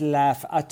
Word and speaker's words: Laff: Is it Laff or Laff? – No, it's Laff. It's half of a French surname called Laffolet. So I cut it in half for pronunciation and spelling purposes, Andrew.